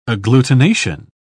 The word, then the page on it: agglutination: British English